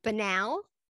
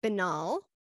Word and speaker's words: banal: It's banal, banal, and banal. – Anal. – I'm just telling you. And Merriam-Webster says all three pronunciations are acceptable in educated speech.